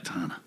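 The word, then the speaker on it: Khetanna: I mean, it's spelled real different than the sword katana. It's K-H-E-T-A-N-N-A, but katana. That's the way I would naturally want to pronounce it.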